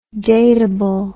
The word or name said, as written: dateableness